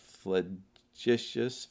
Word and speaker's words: flagitious: I had to look up flagitious. I had it right. FLAG, flag, ITTIOUS, but it's, the G is pronounced J, so it's flagitious, and that word means horribly wicked.